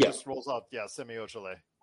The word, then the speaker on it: Ojeleye: Some good things that Milwaukee did was they signed Semi Ojeleye. I believe that's how you say it. – Yes. – It's close, if not it.